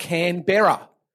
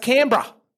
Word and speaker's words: Canberra: Now, our nation's capital is called, if you want to read our nation's capital, it looks like it's spelt Canberra. But no. It's pronounced Canberra. Canberra, mate. Canberra. So you've just got to drop.